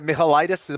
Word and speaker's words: Michailidis: Yeah, listen, I think that you know, the Greek fighter there, Michailidis, am I saying that right? – Yeah.